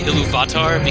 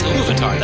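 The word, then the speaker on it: Ilúvatar: I knew you read it because you say Iluvatar, because that's how he says it in the audiobook, and he puts the accent on the wrong. It's Iluvatar. That's how you should say it.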